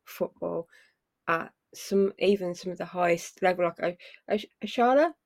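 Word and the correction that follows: Oshoala: is it? – Oshuala, yeah, for Barcelona.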